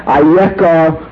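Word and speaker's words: Ayeka (אַיֶּכָּה): The word, as it appears in the text of the Chumash, is spelled as Eicha, Aleph Yud Chaf Hei, but it is pronounced in its context as Ayeka.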